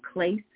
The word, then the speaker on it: kleis: that was K-L-E-I-S, and it's pronounced klais. And it is power and authority. To bind is deo, and it is D-E-O, deo, and it means to forbid, prohibit, declare as improper or unlawful.